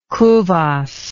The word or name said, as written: kuvasz